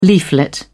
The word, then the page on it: leaflet: British English